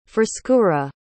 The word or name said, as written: frescura